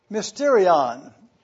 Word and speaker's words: mysterion: It is the word mysterion.